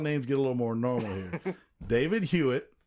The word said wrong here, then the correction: Hewlett: I didn't even pronounce it right. It's Hewlett. Jeez.